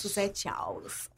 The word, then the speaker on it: Susete Alss: Suzette Aus.